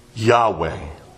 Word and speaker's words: Yahweh: And that Hebrew verb is pronounced Yahweh.